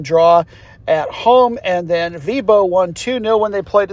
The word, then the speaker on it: Viborg: It looks like Viborg, but it turns out it's pronounced Vibo.